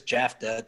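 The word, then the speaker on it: Jaftha: You can find me at Chris Jafta. Don't spell my surname wrong. Everybody, well, not everybody gets it wrong. Those that actually take the time, spell it right. It's J-A-F-T-H-A.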